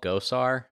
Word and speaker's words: Gosar: Arizona representative Paul Gosar. G-O-S-A-R.